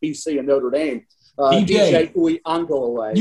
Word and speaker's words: Uiagalelei: So, Uiagalelei.